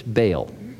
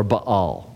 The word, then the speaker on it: Baal: These were people who lived in Israel and were Jewish, but they worshipped Baal, or Baal, you can pronounce it either way.